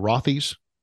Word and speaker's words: Rothy's: Is it Rothy's? – Yep, correct.